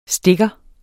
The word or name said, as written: stikker